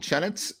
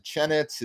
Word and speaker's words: Chenetz: People always mess up my name, and it's, you know, it's, is it Chenitz? Is it Chenay? – So I'm there with you.